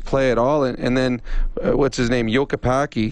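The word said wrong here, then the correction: Jokipakka: Is that how I pronounce it? – Jokapaka.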